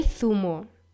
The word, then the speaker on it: Zumo: Neuntens, El Zumo, der Saft. Falsch ist es, wenn man das Z als S ausspricht. Es muss als Z ausgesprochen werden.